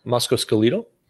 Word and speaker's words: musculoskeletal: If you went to, let's say, a clinic on, I don't even know how to say the word, musculoskeletal, whatever. – Musculoskeletal? – Yeah, musculoskeletal. There you go.